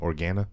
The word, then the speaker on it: Organa: How do you say the last name? Organa? Is that what it is? Boom, first try.